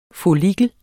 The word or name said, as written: follikel